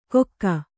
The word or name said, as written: ಕೊಕ್ಕ